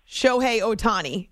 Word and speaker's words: Shohei Ohtani: Shohei Ohtani is how you say it, but yes.